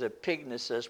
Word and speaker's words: epignosis: What she did not have was, the correct pronunciation is epignosis, but I always say epinosis, which means perfect knowledge.